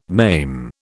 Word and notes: maim: British English

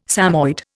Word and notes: Samoyed: Indian

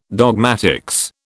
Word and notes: dogmatics: British English

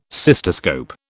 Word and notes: cystoscope: British English

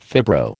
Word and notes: fibro: American English

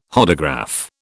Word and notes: Hodograph: British English

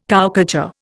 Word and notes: cowcatcher: Indian

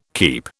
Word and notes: keep: British English

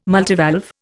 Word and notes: multivalve: Indian

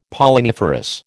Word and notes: Polliniferous: American English

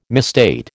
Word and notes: misstate: American English

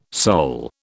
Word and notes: soul: British English

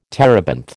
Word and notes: terebinth: American English

